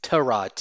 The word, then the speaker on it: Tyrod: And there's an interview he gave, press conference when he was with the Browns, and he says it's Tyrod, T-U-H-R-O-D, Tyrod, or however you might say it with whatever accent you have. So it is Tyrod, just FYI.